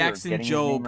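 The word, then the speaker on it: Jackson Jobe: And I feel like the draft pick of Jackson Job, if I'm saying that correctly. – Jackson Job, right.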